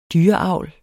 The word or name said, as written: DYREAVL